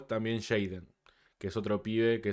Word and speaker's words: JXDN: Jaden. Pasa que se escribe X.